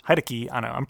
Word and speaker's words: Hideki Anno: I'll believe you that's how it's pronounced. – Hideki Anno.